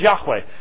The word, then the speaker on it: Yahweh: And the best pronunciation is Yahweh.